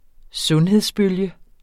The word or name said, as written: sundhedsbølge